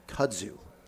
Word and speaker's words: kudzu: Well, you're the only guy on the East Coast in history that pronounced it right the first time. – Nice. – It's kazoo, it's kudzu, it's kudzu, and it's okay.